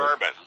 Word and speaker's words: Bourbon: Bourbon. Just bourbon. Like the dirty.